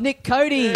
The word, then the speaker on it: Nick Cody: Please welcome into the Little Dum-Dum Club, Nick Cody.